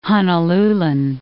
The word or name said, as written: Honolulan